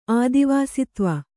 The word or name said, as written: ಆದಿವಾಸಿತ್ವ